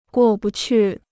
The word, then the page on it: 过不去: guò bu qù